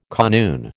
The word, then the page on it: kanoon: American English